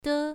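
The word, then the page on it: de: tone 1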